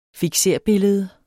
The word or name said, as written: fikserbillede